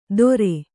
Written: ತೊರೆ

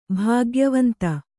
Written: ಭಾಗ್ಯವಂತ

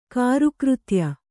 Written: ಕಾರುಕೃತ್ಯ

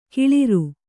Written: ಕೊಳ್ಳಾರ

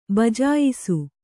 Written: ಭಜಿಯಿಸು

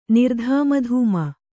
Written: ನಿರ್ಧಾಮಧೂಮ

